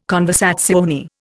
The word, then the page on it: conversazione: Indian